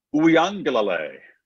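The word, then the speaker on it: Uiagalelei: Uyangalale.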